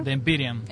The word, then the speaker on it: The Empyrean: Publicó el 20 de enero de este año un disco que se llama, a ver si alguien lo quiere pronunciar, The Empyrean.